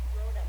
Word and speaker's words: Rhoda: Rhonda, isn't it Rhonda? – Rhoda. – Rhoda. I always say it wrong.